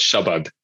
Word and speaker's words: Shabad: But no, it's pronounced Shabad. Which is a very, very flat, unemphasized version of that word.